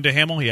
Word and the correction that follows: Duhamel: It's Dumel. It's spelled D-U-H-A-M-E-L.